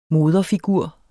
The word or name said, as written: moderfigur